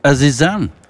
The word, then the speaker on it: Azizam: Azizam, jeg vet ikke om jeg uttaler det rett. Azizam. – Azizam. – Ja, det hørtes rett ut.